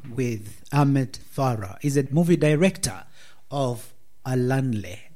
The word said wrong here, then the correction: Ayanle: I hope I said it correctly. Did Ahmed I say Farah. It correctly? Ahmed. Alanle. – Ayanle, yes. – Ayanle, yes.